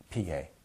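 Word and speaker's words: sgam: And then gam is actually put a small s in front of the G, please. A small s, a silent s. Gampa.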